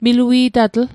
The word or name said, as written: milui'tatl